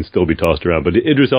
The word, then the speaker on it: Idris: Eldris? – Idris Elba, yeah.